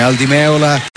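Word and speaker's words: Al Di Meola: Aldi Meola, sarebbe giusto pronunciare così il suo nome, lo conosciamo come Aldi Meola,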